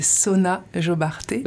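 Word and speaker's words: Sona Jobarteh: Sonia Robarté je sais pas si je le prononce bien ouais c'est Sona Jobarté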